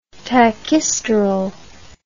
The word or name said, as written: tachysterol